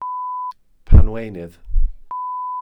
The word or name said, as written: panweunydd